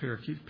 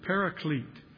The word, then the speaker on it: paraclete: Some people read that and they mispronounce it parakeet. It's not parakeet, paraclete.